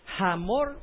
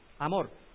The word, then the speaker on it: Hamor: ¿Cómo se pronunciaría ese? ¿Hamor o Amor? Amor. Para nosotros, ahí está la corrección. Para nosotros la H es muda.